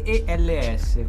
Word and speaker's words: Eels: EELS, come lo pronunciamo? – Eels.